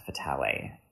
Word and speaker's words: Vitale: It's written by Alex, I believe his last name is pronounced Vitale, and who is one of our New York professors.